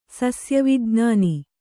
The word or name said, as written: ಸಸ್ಯವಿಜ್ಞಾನಿ